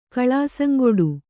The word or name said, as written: ಕಳಾಸಂಗೊಡು